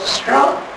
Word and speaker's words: Strachan: And the little town is spelled, I wish I had a blackboard, it's spelled S-T-R-A-C-H-A-N. S-T-R-A-C-H-A-N. And I wanted for years to call that Strachan or something. But if you go there, the people call it strong. Now how you get strong out of S-T-R-A-C-H-A-N, I'll never know.